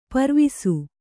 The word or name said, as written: ಪರ್ವಿಸು